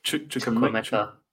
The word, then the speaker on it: Chukwuemeka: And I've got 4-star Chukwuemeka. How do you pronounce his name? – Chukwuemeka. – Chukwuemeka, yeah.